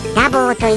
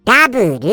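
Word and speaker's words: double: なんといつもいつもあのダボーでくれるのでございますよ。ダボーで、なんだよリスのダボーってって思われるかもしれませんけれども、ダボーと言ったら僕は発音が良すぎるのでダボーって言っちゃいますけど、ちょっと日本的に言うとダブルでございますかね。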